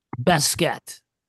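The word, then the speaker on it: Baskette: Now, am I saying his name right? Is it Basket or Basket? – Basket.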